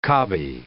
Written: cobby

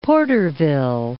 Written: Porterville

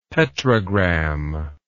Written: petrogram

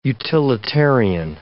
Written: utilitarian

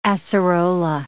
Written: acerola

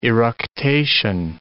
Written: eructation

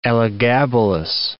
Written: Elagabalus

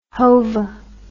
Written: Hova

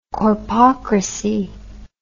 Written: corpocrat

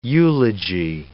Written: eulogy

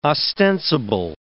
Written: ostensible

